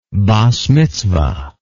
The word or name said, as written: bas mitzvah